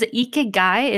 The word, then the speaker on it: ikigai: Can you tell me, what was ikigai? Is that what that's called? – Yeah, it's spelled I-K-I-G-A-I.